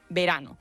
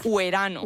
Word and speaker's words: verano: Por ejemplo, verano podía escribirse V-E o U-E, pero se pronunciaba verano. Bueno, pues casi siempre estaba claro si se pronunciaba como vocal o si se pronunciaba como consonante. No ibas a decir brno o huerano.